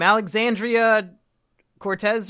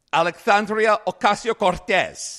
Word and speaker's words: Alexandria Ocasio-Cortez: Alexandria Cortez or something? – No. You said it in a way that makes you sound real racist. You have to say it like this.